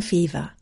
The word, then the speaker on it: Feaver: That was Jane Fever. You can find out more about Jane on the RLF website. Her surname is spelt F-E-A-V-E-R.